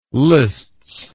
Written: lists

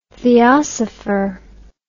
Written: theosopher

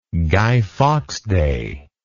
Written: guy fawkes day

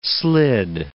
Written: slid

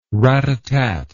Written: ratatat

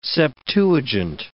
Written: Septuagint

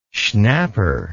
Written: schnapper

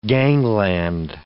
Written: gangland

